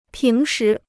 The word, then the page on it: 平时: píng shí